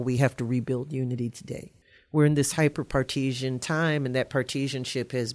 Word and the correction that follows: hyperpartisan: Partesian, part, part, I'm from Louisiana. We don't pronounce anything properly. Our first language is Creole, and it messes us up. So, hyperpartisan.